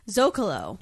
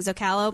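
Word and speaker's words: zócalo: On numerous episodes, I've heard a mispronunciation of the Spanish word zocalo. – Uh-oh. – The accent mark gives emphasis to the first syllable, zo.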